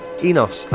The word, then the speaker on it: Inos: And I think the other guy's name is pronounced Enos, I-N-O-S.